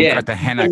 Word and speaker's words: Cartagena: All the way from Cartagena, Colombia. – And say like Cartagena with an N-Y, but it's Cartagena.